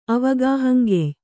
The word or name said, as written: ಅವಗಾಹಂಗೆಯ್